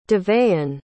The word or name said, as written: deveon